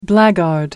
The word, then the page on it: blackguard: American English